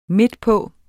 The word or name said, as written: midtpå